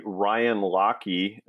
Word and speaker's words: Lockee: I believe I am the only Ryan Lockie, and my last name is spelled L-O-C-K-E-E.